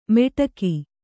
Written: ಮೆಟಕಿ

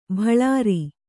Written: ಬಳರಿ

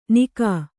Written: ನಿಘ

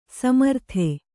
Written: ಸಮರ್ಥ

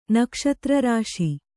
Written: ನಕ್ಷತ್ರರಾಶಿ

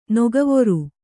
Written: ನೊಗವೊರು

